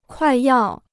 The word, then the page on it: 快要: kuài yào